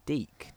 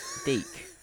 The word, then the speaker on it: DiC: It was produced by, it was made by Deek, D-I-C. – Excuse me? – Deek. And I think this is something that has stuck with me. – Is that really how you say it? – Yeah, because